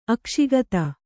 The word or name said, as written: ಅಕ್ಷಿಗತ